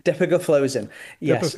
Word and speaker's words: dapagliflozin: And then forgive me if I'm pronouncing this poorly, but dapagliflozin? – Dapagliflozin. Yes.